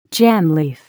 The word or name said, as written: jam leaf